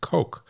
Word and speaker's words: Koch: So what I'd like to do is first start out today by thanking Mr. Tim Koch, and I'm going to pronounce it Koch because it's spelled K-O-C-H, but I know it is the fertility company that is spelled the same way, and they call it and they pronounce it Coke.